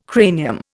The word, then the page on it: cranium: Indian